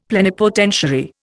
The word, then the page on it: plenipotentiary: Indian